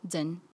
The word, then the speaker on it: Zeng: It's spelled Z E N G and okay. it's pronounced Zhen I like um, that.